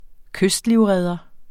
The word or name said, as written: kystlivredder